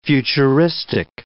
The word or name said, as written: futuristic